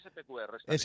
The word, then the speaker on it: SPQR: Nunca sé si pronunciar Spor o SPQR, porque ¿cómo lo pronunció? ¿Cómo se pronuncia bien esto? – No, SPQR.